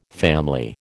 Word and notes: family: American English

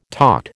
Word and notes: taught: American English

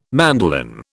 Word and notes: mandolin: British English

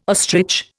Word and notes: ostrich: Indian

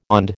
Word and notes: OND: American English

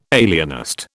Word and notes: alienist: British English

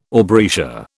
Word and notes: aubrietia: British English

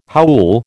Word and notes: howl: American English

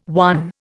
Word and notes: won: Indian

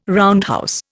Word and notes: roundhouse: Indian

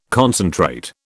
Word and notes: concentrate: British English

